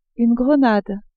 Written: grenade

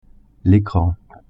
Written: ecran

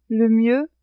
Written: le mieux

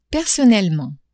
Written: personnellement

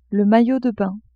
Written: maillot de bain

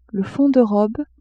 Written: fond de robe

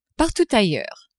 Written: partout ailleurs

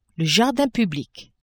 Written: jardin public

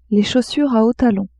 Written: chaussures a hauts talons

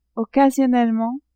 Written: occasionnellement

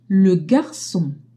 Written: garcon

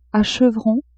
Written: a chevrons

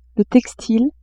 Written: textile

